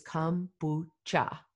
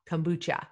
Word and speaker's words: kombucha: Her goal was simply to get a sample into our bellies and to also get us to pronounce it correctly. So it is kombucha, kombucha. So Spark Kombucha is a handcrafted organic kombucha, and it, she began serving this unique beverage, which is dry and brewed.